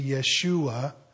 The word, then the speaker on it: Yeshua: Well, Jesus' name, the Americanized version is Jesus. It's actually Yeshua. That would be the American pronunciation of the Hebrew name.